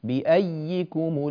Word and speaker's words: بأيكم: بأيكم المفتون؟ – لا لا، هذه ياء واحدة. نعم كتبت ياءان لكن ننطق بواحدة مشددة. بأيكم المفتون؟ – بأيكم المفتون؟